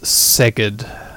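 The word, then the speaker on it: Szeged: Hungary doesn't pronounce Zs like the Polish do. Hungarians have normal sounding Zs, don't they? So it's not Zerged, is it? It's not Zerged. Editor Dan, is it Zerged? No, it's Zerged.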